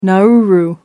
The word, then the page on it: Nauru: American English